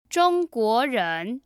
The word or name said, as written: zhongguoren